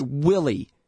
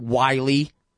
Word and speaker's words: Willey: It's Willie, you damned fool, not Wiley. It's got two L's.